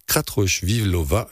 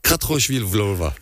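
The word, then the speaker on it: Kratochvílová: Mais Hutchinson et Vero se livrent cette saison un duel avec en ligne de mire le record du monde de Jamila Kratochvilova, vieux de 43 ans. – Bravo, belle prononciation. – Kratochvilova, voilà.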